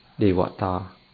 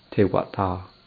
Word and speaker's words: Devata: 秋天，loài trời trong tiếng Pali là Devata, người Thái đọc là Thevata, được định nghĩa là một chúng sanh có ánh sáng.